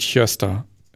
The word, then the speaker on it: Gösta: He äh, startet eine schwedische Serie namens Hjösta. Und ich habe es dann nebengeschrieben, weil das wird Gösta geschrieben. Ähm, aber es heißt halt Hjösta.